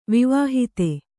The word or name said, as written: ವಿವಾಹಿತ